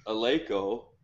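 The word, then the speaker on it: Eleiko: Well, I mean, if you haven't noticed the way she pronounces Aleko, that's like the technical way, right? That's how the Swedes pronounce it.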